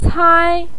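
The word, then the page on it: cai1: tone 1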